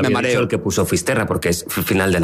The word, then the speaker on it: Fisterra: Entonces llegó a Finisterre, ¿se dice así? – Fisterra. – Es como se pone lo gallego también, en fin.